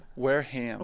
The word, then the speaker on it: Wareham: Okay, so now Wareham or Wareham? – Wareham. – Okay, that's what I thought. – The Wareham Gateman. – Yes, sir. – The H is pronounced.